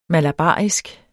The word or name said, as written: malabarisk